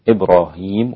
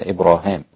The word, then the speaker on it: إبراهيم: تنطق عند الإمام ابن عامر إبراهيم وليس إبراهام، في سورة البقرة إبراهام وبعض المواضع الأخرى في القرآن.